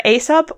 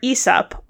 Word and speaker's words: Aesop: Either Aesop or Aesop are considered correct. Although, when I looked at the Greek, the first two letters were alpha iota, which in Greek is, I've learned, is pronounced I, so like Aesop, I would think. But anyway, Aesop or Aesop are both accepted pronunciations in English.